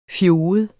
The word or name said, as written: fjoget